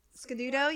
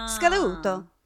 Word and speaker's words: Scaduto: It's Sicilian. So, yeah, it would be Scaduto. That's how they would say it.